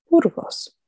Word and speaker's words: Burgos: As for my last name, Burgos, it is the name of the first city in Spain, and the proper Spanish pronunciation is Burgos. However, if that's difficult for people to say, they can say Burgos.